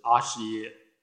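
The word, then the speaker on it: āśaya: 第一种是总名，梵语的发音是阿是耶，在汉地就翻译作意要，是以欲信圣解这三法为它的自体性。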